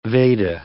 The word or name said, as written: Veda